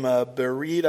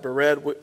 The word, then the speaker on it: Bered: This other name, Bered, I think is how you pronounce that, Bered, that's unknown.